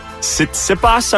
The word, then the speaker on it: Tsitsipas: World number three tennis star Stefanos Tsitsipas, I believe is how you say it. – Yeah, that's right.